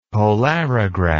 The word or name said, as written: polarograph